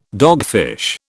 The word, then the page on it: dogfish: British English